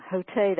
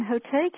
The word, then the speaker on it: Hotei: Hotei, that's spelled H-O-T-E-I, is a 16th Japanese mystic.